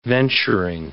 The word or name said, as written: venturing